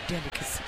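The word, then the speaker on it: D'licacy: So, DI, spell that again, because this brand is quite unique. It's apostrophe LICACY. – Yes. – Delicacy. – So it's _ DLICACY.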